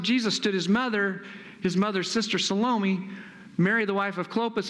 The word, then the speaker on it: Salome: Her name is Salome, not Salami. Salome. I used to pronounce it Salami till I got corrected.